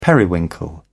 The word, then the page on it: periwinkle: British English